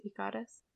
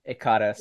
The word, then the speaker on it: Ekiras: It's like Icarus, but Japanese. Ikaris. – Ikaris, yes. – Ikarasu. – Except it's spelled E-K-I-R-A-S, like that. So Ikaris.